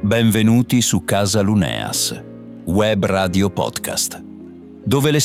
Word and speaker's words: Luneas: È una storia scritta da Virginia Luneas, attenzione alla pronuncia Luneas, e pubblicata da Casa Luneas, anche qui Luneas, nel loro progetto Scritture senza tetto.